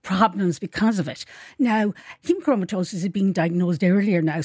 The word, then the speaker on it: hemochromatosis: Kathy says she has hemochromatitis. – Can she? Hemochromatosis.